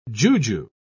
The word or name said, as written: juju